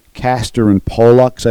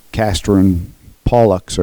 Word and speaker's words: Castor and Pollux: Castor and Pollux, I believe, is how it's pronounced.